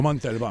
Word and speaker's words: Montalban: The Montalban. – You have to say it like that.